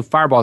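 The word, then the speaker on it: Firebaugh: I probably should spell it because I know Fireball is not a very common name in many parts of the country.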